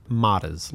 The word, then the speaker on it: Matas: Matas. Like, Natas.